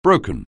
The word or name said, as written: broken